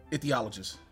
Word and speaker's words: ichthyologist: Ichthyologist. Say that one some more. Ichthyologist.